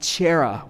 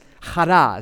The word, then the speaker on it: chara: That joy word is chara. It looks like chera when you say it, but it's chara is the way it's pronounced.